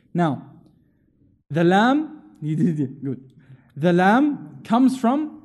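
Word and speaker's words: Lam (لام): It's coming from your tongue, from the middle of your mouth. Right? It's not coming from the back of the throat. Alif. It's coming from the back. Lam.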